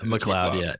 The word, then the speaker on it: McLeod: I always hear different pronunciations of his last name. – McLeod. – McLeod. Yeah. Okay. That's what I figured.